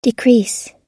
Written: decrease

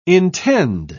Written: intend

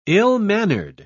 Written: ill-mannered

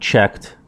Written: checked

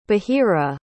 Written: bahira

